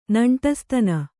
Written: ನೆಂಟಸ್ತನ

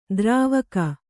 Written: ದ್ರಾವಕ

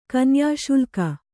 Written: ಕನ್ಯಾಶುಲ್ಕ

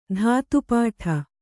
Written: ಧಾತುಪಾಠ

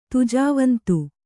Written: ತುಜಾವಂತು

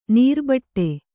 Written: ನರಿಬೇಟೆ

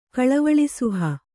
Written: ಕಳವಳಿಸುಹ

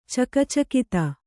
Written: ಚಕಚಕಿತ

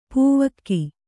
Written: ಪೌವಗೆ

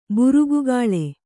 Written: ಬೆರಗುಗೊಳ್

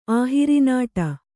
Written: ಆಹಿರಿನಾಟ

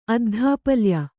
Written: ಅದ್ಧಾಪಲ್ಯ

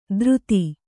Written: ಧೃತಿ

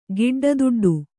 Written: ಗಿಡ್ಡದುಡ್ಡು

